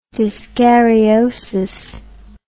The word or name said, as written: dyskaryosis